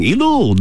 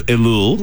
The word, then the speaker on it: Elul: So the first letter of Elul, right, is the Aleph, which is silent. It makes no sound.